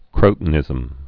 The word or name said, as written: crotonism